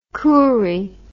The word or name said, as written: kuri